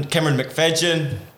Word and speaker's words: McFadyen: That's how you pronounce my last name. – McFadyen. – Yes. – But it's spelled McFadden, like that singer guy. – Actually, there's a Y, and I don't know why there's a G sound, but something along there.